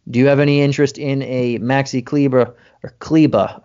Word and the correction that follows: Kleber: Maxi Kleber, I mean, at 4,200, he's priced, you know, maybe near the top of his fantasy output.